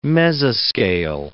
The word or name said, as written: mesoscale